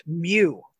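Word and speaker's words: Mu: I don't know if it helps, but in my scientific education, that's pronounced Mew. – Mew. – Like a kitty.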